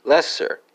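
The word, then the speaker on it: leser: That's going to be Lego, spelled L-E-G-G-O. And for Norwegian, Swedish, and Danish friends, you have Lesser, Lassar, and Leiser, respectively, spelled L-E-S-E-R, L-A-S-A-R, and L-A-E-S-E-R.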